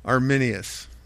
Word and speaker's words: Arminius: Arminius.